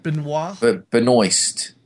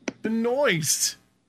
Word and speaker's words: Benoist: Benoist. Benoist.